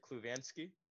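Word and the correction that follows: Kulusevski: Kulishevsky, yeah. Kulishevsky. – Yeah. – He was good. – Pronounced it perfect.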